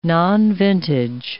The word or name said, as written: nonvintage